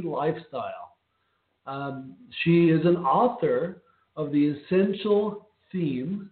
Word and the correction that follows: Thyme: Is that right? Pronounce it? – Essential Thyme. – Thyme. See, I always do that.